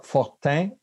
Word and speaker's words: Fortin: Well, it's Fortin, hein? Okay. That's a little difficult of a nasal sound for most native English speakers, but that's okay.